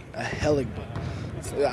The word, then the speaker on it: Ahelegbe: On paper, it's K-W-A-D-Z-O. It's Kajoe. And the last name is A-H-E-L-E-G-B-E, Ahelegbu.